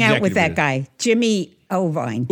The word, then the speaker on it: Iovine: I think it's Iovine or something. But he's a legendary record producer. – It's Iovine.